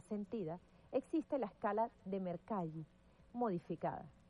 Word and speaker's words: Mercalli: Mercalli está bien. Digamos que, por cuestiones, hay muchas similitudes en cuanto al italiano con el español. Y Giuseppe Mercalli era justamente italiano y se puede pronunciar de esa manera.